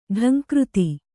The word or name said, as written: ಢಂಕೃತಿ